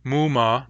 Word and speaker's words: muma: Whereas muma, there are four, has a long, long pattern.